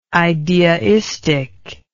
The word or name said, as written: ideaistic